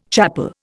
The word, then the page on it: chapel: Indian